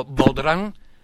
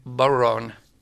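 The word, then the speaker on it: bodhrán: E la pronuncia, sì, tutti lo scrivono e tendono a pronunciarlo Bodran, ma la pronuncia anglofono è Baron.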